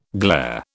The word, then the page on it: glair: British English